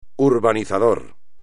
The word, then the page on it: urbanizador: espagnol